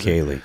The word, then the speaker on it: Bukele: It's Bukele. – Nayib Bukele, that's how you say it? – Nayib Bukele, yeah.